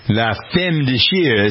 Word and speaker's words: La Femme de Chair: The painting, and I don't know if I'm pronouncing this correctly, La Femme de Chair. – La Femme de Chair. That sounds very good.